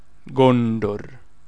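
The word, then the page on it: Gondor: Sindarin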